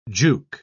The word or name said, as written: juke